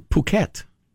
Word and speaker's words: Phuket: Judy and I considered going to Phuket.